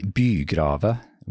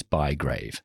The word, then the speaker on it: Bygrave: Well, the Norwegians would sometimes say Bygrave. – I love it. Sounds so good. – Yeah. It actually means a city grave or city diggings. So it's not a particularly uplifting surname to have. But I keep trying to insist on the English pronunciation, which is Bygrave. – Bygrave. Yeah, yeah. – As in Max, but no S.